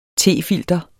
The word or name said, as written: tefilter